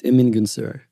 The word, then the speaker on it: Emin Gün Sirer: I can never say it right, and I feel like I've had a go at it. Please say it for me. – You said it exactly right. Emingun Sira.